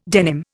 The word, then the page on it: denim: Indian